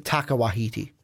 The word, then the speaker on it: Taika Waititi: No, that's is that how you pronounce it? – Taika Waititi.